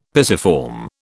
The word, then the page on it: pisiform: British English